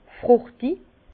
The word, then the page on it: frùchtig: Bas Rhin; Herrlisheim